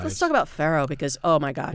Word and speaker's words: farro: One is farro is an ancient relative of wheat. You see it in Italian cooking a lot. It is slightly ridiculous to say it correctly in Italian. Farro.